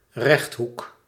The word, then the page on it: rectangle: American English; [ˈrɛkˌtæŋ.gəl]